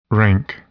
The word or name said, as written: rank